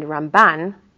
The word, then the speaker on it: Ramban: Rambam, we emphasize the first part of the name. And Ramban, it's the second part of the name.